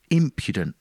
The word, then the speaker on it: impudent: So once I learned that, I knew it was impudent, with the emphasis on the first syllable, which you would never guess from reading the word.